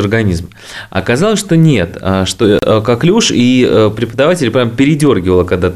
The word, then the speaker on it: коклюш: Да, по поводу коклюша. Коклюш или коклюшево. Значит, как учили меня на кафедре инфекционных заболеваний, правильно говорить коклюш.